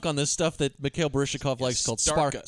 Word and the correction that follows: Starka: Starka. And Gene Hackman constantly mispronounces it as Storka and Stinka and like all kinds of other shit.